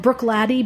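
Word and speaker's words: Bruichladdich: It's made by it's called Brook Laddie, but it's spelled as if it's Brick Laddick.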